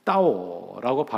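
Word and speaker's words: 따오 (到): 이 중국어의 뒤집다라고 하는 뜻이 뜻을 가진 단어와 도착하다는 이 중국어가 따오라고 발음이 같아서 이 복자를 거꾸로 붙여놓게 되면 복이 따오 도착했어요, 복이 왔어요라고 하는 의미가 된다는 겁니다.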